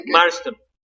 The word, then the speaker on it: Marston: Marston. Se escribe M-A-R-S-T-O-N. – William Moulton, Moulton Marston, ¿no? – Moulton Marston, sí.